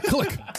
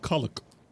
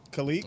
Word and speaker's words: Kalik: I said click. – Damn. – Click. – Kalik. – Kalik. – It's called Kalik. – Hell yeah. – That's a click. Terrible way to pronounce it.